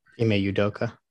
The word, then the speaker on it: Ime Udoka: I still don't know how to say his name, but Ime Udoka.